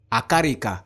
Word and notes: akarika: Efik

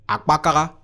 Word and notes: akpakaga: Efik

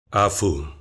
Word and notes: afo: Efik

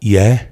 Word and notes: Ye: female

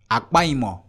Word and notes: akpaimor: Efik

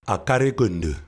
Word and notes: Akarikondo: LHHLL

